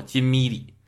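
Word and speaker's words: 金日磾: 于是全国人民欢欣鼓舞，他晚年发生了这么一件事，这个事件就直接指向了两个人，他们就是整个事件的幕后推手，一个人叫做霍光，一个人叫做金咪里。金是金子的金，那个咪是个多音字，是太阳，就是日出的那个日子，狄是十字旁加个繁体的单个的单，那么个字儿。大家在课程的文案里面也找得到这个人名啊，大家可以对照着看一下。那这个金咪里是谁呢？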